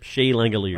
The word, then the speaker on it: Langeliers: Langeliers? – Yep, Shea Langeliers.